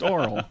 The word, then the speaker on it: aural: Because they're both pronounced oral.